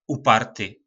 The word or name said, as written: Uparty